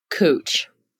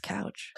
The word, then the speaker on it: Couch: It's spelled like couch. – That's the obvious answer. – And so we should pronounce it couch. – Yeah. – But it's cooch, which just sounds sexual.